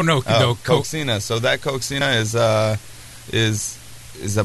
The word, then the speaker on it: coxinha: All right, and then there was another thing on there that was COXINHA. How do you say that? – Coxina.